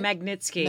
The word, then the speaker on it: Magnitsky: And then he also voted against, and I'm going to say it wrong, Magnitsky Act? Is that it? – Magnitsky.